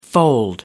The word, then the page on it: fold: American English